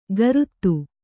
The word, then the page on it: ಗರುತ್ತು: Kannada